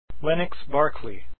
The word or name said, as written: Berkeley